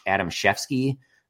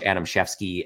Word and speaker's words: Adamczewski: And then the last one is Josh Adamshefsky. The only reason that I'm somewhat confident in the pronunciation there is that seems like a very German sort of Wisconsin name. It is spelled Adamczewski.